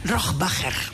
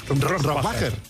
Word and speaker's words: Rohrbacher: Bueno, ¿cómo se pronuncia Alba Rohrbacher Coixet? – Rohrbacher. – Rohrbacher.